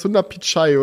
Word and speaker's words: Sundar Pichai: Es war dann Sunda Peach. Äh, fuck, wie, wie, spricht man ihn aus? Sundar Pichai, oder?